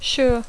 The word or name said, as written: sure